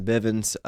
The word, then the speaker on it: Bivens: I'm Jackson Bivens.